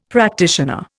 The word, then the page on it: practitioner: Indian